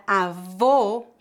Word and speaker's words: avô: A palavra avô com acento circunflexo refere-se ao pai da sua mãe ou do seu pai. A pronúncia dessa palavra é feita com o fechado.